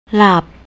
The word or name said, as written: lap